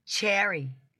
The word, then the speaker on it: Cheri: Cherry. – Did I say Sherry? – It does look like Sherry, and it's spelled like Cherie. So it is hard to find my record, C-H-E-R-I.